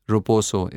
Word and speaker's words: Roposo: Then you bought a short video platform, Roposo. Is that how you pronounce it? – That's correct.